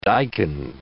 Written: daikon

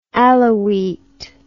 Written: Alaouite

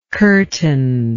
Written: curtainless

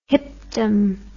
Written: hipdom

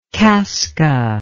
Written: kaska

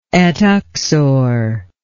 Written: et uxor

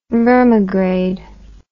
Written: vermigrade

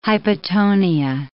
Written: hypotonia

